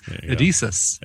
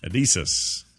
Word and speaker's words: Edeesis: It's spelled E-D-E-E-S-I-S. Edesis. – Edesis.